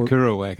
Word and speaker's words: Kerouac: The Kerouac effect, yes. – Oh, is that how you say it? Jack Kerouac? – Yeah.